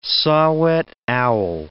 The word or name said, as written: saw-whet owl